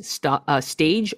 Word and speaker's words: stage: It's pronounced S-T-A-J, and it's spelled S-T-A-G-E, stage or stage.